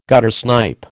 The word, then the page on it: guttersnipe: American English